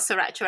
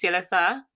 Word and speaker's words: Sriracha: It's not sriracha at all. If you say it the Asian way, it's silasa.